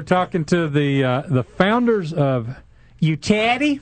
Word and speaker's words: Yewchati: Yewchati. – Yewchati.